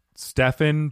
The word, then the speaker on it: Stephen: It's, I feel your pain because my name is Stephen, but it's spelled S-T-E-P-H-E-N, so I get Stephen.